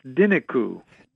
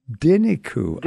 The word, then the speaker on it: Dinicu: Well, it's Dinicu. – Dinicu. – Dinicu. Uh-huh. Yeah, I wasn't sure myself. I know the name because he was a great violinist in Romania, violinist and composer, influenced people like Stefan Grappelli and Yasha Heifetz that played his music. And so they took that name because they're basically a gypsy jazz trio. That's how it's pronounced.